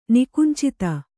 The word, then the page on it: ನಿಕುಂಚಿತ: nikuncita